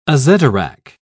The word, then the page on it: azedarach: British English